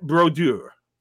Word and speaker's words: Brodeur: So to me, the number one, number 30, has to be Martin Brodeur. Did I pronounce that well? – Yes, I liked it.